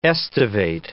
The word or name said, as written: aestivate